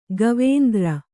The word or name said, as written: ಗವೇಂದ್ರ